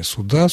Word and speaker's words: суда: При склонении существительного «суд» в формах косвенных падежей ударение падает на окончание «суда», «суду», «судом», «о суде», «на суде» и так далее.